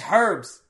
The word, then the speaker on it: herbs: No, it's just that's the American pronunciation of herbs. They're like, it's herbs! Pronounce the H!